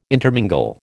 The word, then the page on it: intermingle: American English